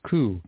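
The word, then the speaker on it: ku (九): It goes for the, okay, the number 9 is almost as bad because it's pronounced ku, K-U, the same as the word for suffering.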